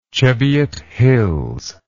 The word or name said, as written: Cheviot Hills